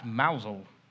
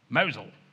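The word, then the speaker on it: Mousehole: That's Mousel. Which is spelled Mouse Hole. All right. But it's so named because while the harbour itself, the basin, the water that you can there see a dragon in, but normally has boats, that's a decent size. But the gap through which the boats have to get into the harbour is very narrow, very small, hence Mouse Hole. Just pronouncing Cornwall, Mosel.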